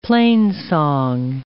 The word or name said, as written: plainsong